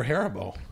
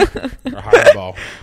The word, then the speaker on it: Haribo: They were Haribo. – Oh. – Or Haribo. How do you pronounce it? – I like it? – Haribo. – Yeah, Haribo. I think it is. I think it's Haribo, though.